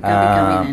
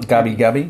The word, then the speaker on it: Gubi Gubi: There is, but yeah, the First Nations people were definitely the Gabi Gabi, which is spelt G-U-B-I, G-U-B-I. – Okay, Gabi Gabi then. – Gabi Gabi, yeah.